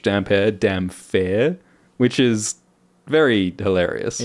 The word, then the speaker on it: Damphair: It is pronounced damp hair. – Yeah, I don't want to gaslight everybody. So Aeron Dampere... That's how it's pronounced, Dampier, and it's spelled Damphair.